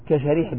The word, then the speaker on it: شريح: قال: أو بالعكس، كأن تختلف الأسماء نطقا وتأتلف خطا وتتفق الأباء خطا ونطقا، كشريح بن النعمان وسريج ابن النعمان. قال: الأول بالشين المعجمة والحاء المهملة وهو تابعي يروي عن علي، والثاني بالسين المهملة والجيم وهو من شيوخ البخاري.